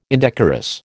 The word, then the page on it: indecorous: American English